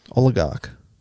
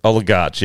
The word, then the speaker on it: oligarch: Oligarch, yeah. – No, I wouldn't say garch. – You would. Okay, good listeners. – How are you doing as a gag? I wasn't. It's spelled O-L-I-G-A-R-C-H. Oligarch.